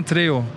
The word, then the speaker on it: Trejo: Ja, Dani Trejo, Trejo heißt er. Trejo wird wahrscheinlich ja. ausgesprochen, das kannst du besser. Ah, Treo.